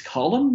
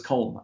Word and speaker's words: Colm: It's Colum, meaning? – Colum, I didn't, yeah. – I thought it was Colm.